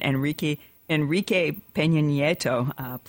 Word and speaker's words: Enrique Peña Nieto: He flew down to Mexico to meet with the Mexican president, Enrique Peña Nieto. Please forgive me if I mispronounce his name. – No, that's correct.